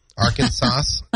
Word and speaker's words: Arkansas: In the state of Arkansas, it's against the law to pronounce the silent S at the end of the state's name. Arkansas, you could go to jail.